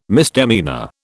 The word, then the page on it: misdemeanour: British English